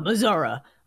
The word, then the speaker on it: Missouri: Ooh, and bonus points on the correct pronunciation of Missouri. That's how you say Missouri. – Down in Missouri.